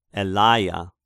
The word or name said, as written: Elaia